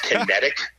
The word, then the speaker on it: Kinetic: And I don't want Paige Van Zandt and myself to say Kinetic. So it sounds like a mispronunciation of Connecticut. So no, it's Kinetic is what we're going with.